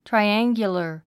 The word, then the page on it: triangular: traiǽŋɡjulər; トライアンギュラァ